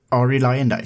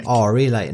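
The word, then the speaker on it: Arie Luyendyk: Ari Lyndike. – Ari Lyndike. Det är ju blivit väldigt amerikaniserat. Ari Lyndike där.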